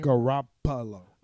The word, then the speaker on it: Garoppolo: Garoppolo. Isn't that his name? – Garoppolo.